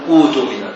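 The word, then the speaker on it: اؤتمن: وأتمن، اجتمعت همزتان في كلمة واحدة، الثانية ساكنة والأولى مضمومة، الأولى همزة وصل لكنها يبدأ بها مضمومة من أجل ضم الحرف الثالث، فإذا نطقت تقول أوتمن أوتمن، فتقلب الهمزة الثانية الساكنة تقلبها واوا لأن حركة الأولى الضم وحرف المد الذي جنسه الضم هو الواو، فتقول أوتمن.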